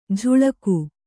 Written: ಝುಳಕು

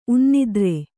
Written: ಉನ್ನಿದ್ರೆ

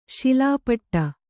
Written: ಶಿಲಾಪಟ್ಟ

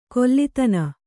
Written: ಕೊಲ್ಲಿತನ

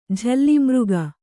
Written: ಜಾಳಮಾರ್ಗ

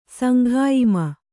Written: ಸಂಘಾಯಿಮ

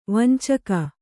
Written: ವಂಚಕಿ

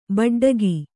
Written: ಬೂಟಾಟಿಕೆ

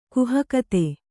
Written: ಗಾಹುಗಾತಿ

